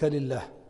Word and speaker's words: الله: ال لا لا ما قبلها ال ال لا ال لا ثم فخموا اللام تبعا لتعظيم مسماه فقالوا ال ها ال الله الله ويعود إلى ترقيق إذا سبق بكسرة كقولك لله